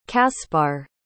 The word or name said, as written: kaspar